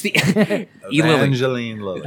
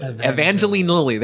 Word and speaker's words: Evangeline Lilly: Evangeline Lilly. – Evangeline Lilly.